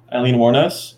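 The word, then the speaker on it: Aileen Wuornos: Eileen Wuornos.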